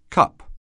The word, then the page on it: cup: anglais